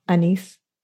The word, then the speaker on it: anise: Um, Great grandma's name uh, was Gracie and had an, an anise. Am I saying that right, Rachel? Anise? – Anise or anise? – Okay. – Either um, one.